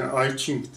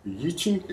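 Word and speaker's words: I Ching: Ai Ching diye e, telaffuz ediyorsunuz. Yi Ching e, vardır.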